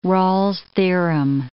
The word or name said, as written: Rolle's theorem